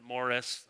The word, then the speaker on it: Maurice: They pronounce it Morris. I don't know, Maurice sounded French or something, but named after his son, Prince Maurice.